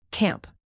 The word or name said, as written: camp